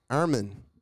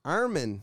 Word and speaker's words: Armin: Armin. – Sorry? – Armin. – Armin. Spell that. – A-R-M-I-N.